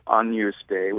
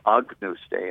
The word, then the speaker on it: Agnus Dei: like instead of Agnus Dei, which would be an Italian version, they'd say Agnus Dei, and there's a hard G. And that informs some of their compositional choices. You know, if you're singing Dei with a closed A instead of Dei with an open, soft E vowel, that changes the color of the chord, it changes all these kinds of things.